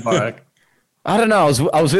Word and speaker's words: Bourke: B-O-U-R-K-E. How do you say it? – B-O-U... Burke. Burke. Okay. Burke.